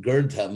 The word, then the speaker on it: girdha: So grid, this verb, or actually you pronounce the root girdha, ma girdha, is really the opposite of tena tyaktena.